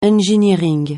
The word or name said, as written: engineering